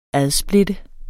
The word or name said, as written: adsplitte